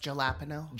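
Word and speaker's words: jalapeño: It's pronounced jalapeno. – Jalapeno.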